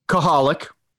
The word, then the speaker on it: Coholic: And I was, now granted, this is kind of a rookie one to do, but Koholik. Well, can you spell that for me? Yeah. C-O-H-O-L-I-C.